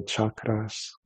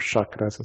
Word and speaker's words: chakras: In India, they'll talk about the seven chakras, or chakras as they pronounce in America.